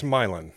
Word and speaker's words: Milan: So you pronounce it Milan. I would pronounce it Milan. – Like, well, it's Milan.